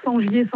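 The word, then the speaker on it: Sengier: Sur mon nom, Sangier Sandrine.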